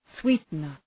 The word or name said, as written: sweetener